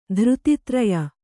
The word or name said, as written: ಧೃತಿತ್ರಯ